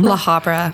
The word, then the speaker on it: La Habra: La Habra. – How do you spell that? – L-A space H-A-B-R-A.